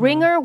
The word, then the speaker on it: Ringr: It's called Ringer. It's called R. You spell it R-I-N-G-R. And Ringer works... – Like Flickr. – Like Flickr, but Ringer.